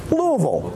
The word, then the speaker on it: Louisville: But Louisville is properly pronounced Louisville, right? And so if you hear somebody say Louisville or Louisville, you right away know they're not from Kentucky.